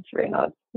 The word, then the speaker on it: Raynaud's: it's something that you struggle with is called Raynaud's. Is that right? – Yes. Yeah. It's Raynaud's.